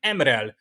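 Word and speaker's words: M'Rel: egyébként Emrel nek ejtik az angolban, meg a magyarban,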